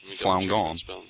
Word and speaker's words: Falun Gong: F-A-L-U... – Flaun Gong. No, you got it. – F-A-L-U-N-G-O-N-G. – Check show notes. – Flaun Gong.